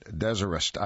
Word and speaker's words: Deserest: And Desirest, I guess, is perhaps the better way to pronounce that. D-E-S-E-R-E-S-T. Desirest Motel.